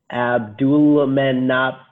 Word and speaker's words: Abdulmanap: I think it's like Andesol. Is that his name? Andesol Nurmagomedov. I know the name wasn't as hard to pronounce as Khabib. – Abdulmanap Nurmagomedov. – Yep.